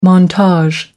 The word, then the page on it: montage: American English